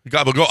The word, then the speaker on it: capicola: Gabagool. So I thought that's what it was. And then when I saw it spelled, I was like, what is this? It looks like Gabagool. But it's spelled Capicola.